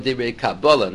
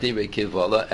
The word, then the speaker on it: Dibre Kabbalah: But Rashi, and the end of Chulin, the Shita Mekubetzes quotes the other Pshat also, the Rashi has the end of Chulin, that you pronounce the word Dibre Kabbalah, not Dibre Kivvalah,